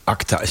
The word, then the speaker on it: Agta: Ja, landrechten voor de ACTA. Zeg ik het goed? De ACTA? – ACTA? Ja, ACTA. – ACTA. – A-G-T-A.